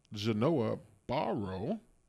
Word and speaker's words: Genoa Barrow: This is the host of The Verbal Radio, here in the studio with Genoa Barrow.